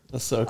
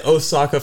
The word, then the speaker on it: Ahsoka: I've been saying Osaka for so long, man. So it's just one of those things that I've said it so for wrong. I said it wrong for so long that it's hard for me to stop saying that.